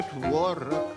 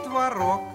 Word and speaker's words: творог: А творог? – Можно творог. – А можно и творог.